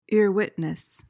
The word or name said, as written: earwitness